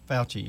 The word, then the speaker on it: Fauci: There's something I heard during the week, and it's about that Dr. Fauci, or however you pronounce his name. – Yes, Fauci, you got it.